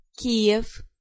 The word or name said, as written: Kiev